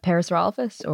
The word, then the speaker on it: Parasaurolophus: Parsifalophus. Am I saying that correctly? – Oh, the Parasaurolophus or? – That's bingo. Yeah, bravo.